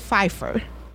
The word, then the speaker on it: Pfeiffer: When they were talking about the show, the P is pronounced. So, you know, like Michelle Pfeiffer mm-hmm. has it or whatever. Mm-hmm. The P, it's Pfeiffer.